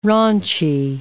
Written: raunchy

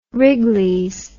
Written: wrigleys

